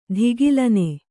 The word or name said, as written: ಧಿಗಿಲನೆ